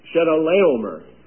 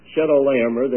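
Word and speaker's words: Chedorlaomer: Now here's one. Shedaleomer. The accent is on the L-A, the third syllable. Shedaleomer,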